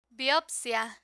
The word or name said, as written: biopcia